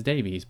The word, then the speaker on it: Davies: Like, the name D-A-V-I-E-S, I would say, is always pronounced Davies. Davis has no E in it. D-A-V-I-S is Davis, D-A-V-I-E-S is Davies, but sometimes they pronounce D-A-V-I-E-S Davis and then vice versa, and it doesn't make any sense, but to each their own, I'm going to say Davies.